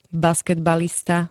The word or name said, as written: basketbalista